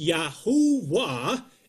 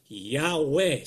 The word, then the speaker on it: Yahuwah: So it is Yahuwah and not Yahweh.